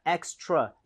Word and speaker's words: extra: 발음할 때 extra가 아니라 extra 이것도 입모양을 좀 Extra. – 모으고 해보도록 하겠습니다.